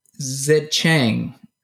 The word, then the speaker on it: Xiaocheng: Tang Zicheng. I don't know if I'm pronouncing that right. X-I-A-O-C-H-E-N-G. – Yeah, that sounds right.